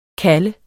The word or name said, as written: kalde